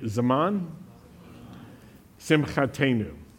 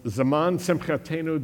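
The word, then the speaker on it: Zaman Simchatenu (זמן שמחתנו): When a word in Hebrew ends in the new sound, N-double-O or N-U, long U, it means our. And so it's Zaman Simchatenu, the season of our joy.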